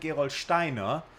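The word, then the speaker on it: Gerolsteiner: We call it Gerold Steiner. Yeah, let's pronounce it the German way.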